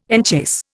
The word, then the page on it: enchase: Indian